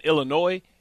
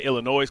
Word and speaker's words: Illinois: And understand, like, in Illinois, I used to say Illinois because it had an S on it. I had to figure out you don't pronounce the S.